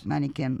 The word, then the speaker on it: Manichaean: And this thing is the state, strong, arrogant, merciless, supported by its Manichaean, do I pronounce it all right? – Yes. – Manichaean laws, its crippling regulation, its pitiless Manichaean interest.